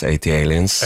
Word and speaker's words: ATLiens: Jeg lærte jo da i senere tid at det uttales 80 Aliens, ja, men det skrives jo ikke sånn.